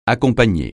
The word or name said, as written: accompagner